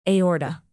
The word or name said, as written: aorta